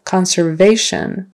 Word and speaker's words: conservation: Conservation. Conservation. Third syllable. Conservation.